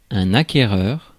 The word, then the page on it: acquéreur: France; [a.ke.ʁœʁ]